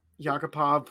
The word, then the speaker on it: Yakupov: Yakupov, Puljujarvi.